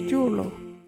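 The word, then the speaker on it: juurlu: the Wajiri word is julo.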